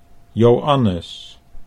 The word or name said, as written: Joannes